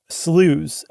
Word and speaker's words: slough: There's actually a couple of what are called sloughs, S-L-O-U-G-H is how it's pronounced. – In America. – Or how it's spelled. In America, you pronounce it slough.